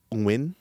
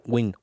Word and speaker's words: Nguyen: It's supposed to be Nguyen. – Yeah, that's what I said. – Say it one more time. – Nguyen, like Nguyen. – That? – I thought it was just Wing. Wing. – No, it's like the NG Nguyen. Sound is like very difficult to pronounce.